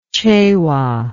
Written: cewa

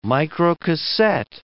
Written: microcassette

